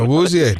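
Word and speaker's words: Awuzie: Owuzie? – Yeah, Owuzie.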